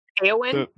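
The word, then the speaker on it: Éowyn: Eowyn? – Yes, thank you.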